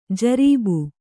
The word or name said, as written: ಜರಬು